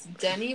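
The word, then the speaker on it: Denis: It's Denny, but it's spelled like Dennis with only one N. – Denny? – Yeah. – Denny. – Cool. – Like the restaurant.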